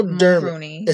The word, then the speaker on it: Dermot Mulroney: I know Dermot. Mulroney.